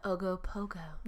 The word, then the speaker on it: Ogopogo: The Ogopogo. – I love it so much. Yeah. Ogopogo.